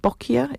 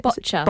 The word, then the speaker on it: Boccia: And some of the activities you've got there is, I think you pronounce it Boccia? – Boccia. – Boccia, sorry, Boccia.